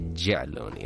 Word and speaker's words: Yaloni: But it's actually Jaloni, if you want to say it right. If you want to say it correctly like me, if you want to sound like a local like me, you say Jaloni.